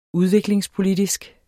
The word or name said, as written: udviklingspolitisk